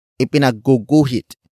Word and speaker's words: ipinagguguhit: Word for word it's, ipinagguguhit ko Si.